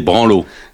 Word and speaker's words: branlos: Non, on dit des branlos. Il y a pas le, on prononce pas le S.